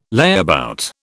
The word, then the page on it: layabout: British English